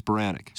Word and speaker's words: Spiranac: Paige Spiranek.